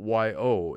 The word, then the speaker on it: Yohe: But Mr. Yo is not spelled Y-O. It's actually a name of a gentleman of Pepper's acquaintance. And although you pronounce his name Yo, it's spelled Y-O-H-E, but pronounced Yo.